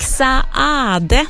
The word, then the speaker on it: Saade: Han stavar ju S-A-A-D-E, och då ska man tydligen uttala båda och en också, så då gör jag det.